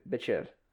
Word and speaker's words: Bichir: Is that how you pronounce that? – B-I-C-H-I-R. Bichir. – This is fucking Damien. – D-E-M-I-A, little tick above it, N.